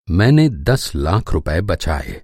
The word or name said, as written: बचाना